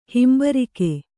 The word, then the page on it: ಹಿಂಬರಿಕೆ: himbarike; kannada